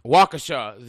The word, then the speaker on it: Waukesha: Did I say that right? – Waukesha. – Waukesha.